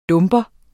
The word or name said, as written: dumper